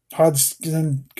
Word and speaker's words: Hodgson: Lovecraft, and the lush weirdness of Clark Ashton Smith and William Hope Hogston. Hodgson, gosh, I can't say his name.